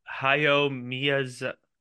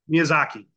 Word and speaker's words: Miyazaki: Hayao Miyazaki. – Miyazaki, thank you.